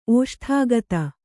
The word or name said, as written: ಓಷ್ಠಾಗತ